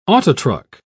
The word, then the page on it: autotruck: British English